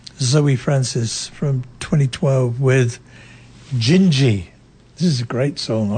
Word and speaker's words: Dindi: Gingy, it's spelt D-I-N-D-I, but pronounced Gingy in Portuguese.